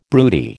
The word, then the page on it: broody: American English